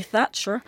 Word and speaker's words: Thatcher: Parce que ces années Thatcher sont le moment... – On remarquera comment vous prononcez beaucoup mieux le nom de Madame Thatcher que moi.